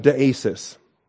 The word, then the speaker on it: deesis: This is deesis. The noun here that's translated supplication is deesis. D-E-E, that's the short E and the long E, epsilon and eta.